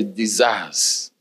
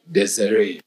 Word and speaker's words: Desiree: So the same thing, but if it's a name of somebody, do you understand? You don't pronounce it desire. It's Desiree. Okay.